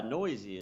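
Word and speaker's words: Neuse: That one run was allowed on a home run by, I believe it's Sean Noisy. – Yeah, Noisy is how you say that.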